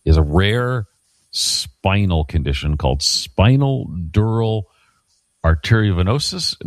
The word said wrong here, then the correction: arteriovenous: No, I'm saying it wrong. Arteriovenous fistula.